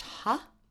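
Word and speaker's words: ha (ห้า): The number 5 is pronounced ha.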